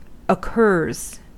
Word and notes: occurs: American English

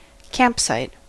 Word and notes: campsite: American English

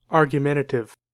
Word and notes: argumentative: American English

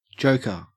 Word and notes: joker: Australian English

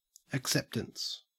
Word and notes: acceptance: Australian English

